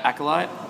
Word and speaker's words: Acolyte: That's how you say it, right? Acolyte? – That's exactly how you say it. Beautiful. Nailed it.